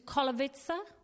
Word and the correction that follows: Katowice: You say it like that? – Katowice. – Oh, excuse me.